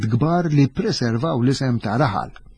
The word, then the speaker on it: raħal: Però anke hemmhekk għandna argument ta' kontra għaliex jekk inti tgħid raħal, raħal għandha l-aċċent fuq l-ewwel sillaba u normalment il-Malti meta jqassar ma jqassarx billi waqa.